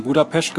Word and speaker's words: Budapest: Du bist in Budapest geboren, wie ich mittlerweile gelernt habe, so spricht man es aus.